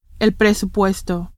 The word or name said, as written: presupuesto